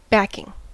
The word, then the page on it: backing: American English